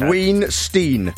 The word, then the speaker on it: Weinstein: Weinstein. – No, the way I spell it is wine, like the drink. – Yeah, and then Steen, but yeah.